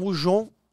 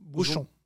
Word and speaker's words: bouchon: Un bouchon es un... – Bouchon.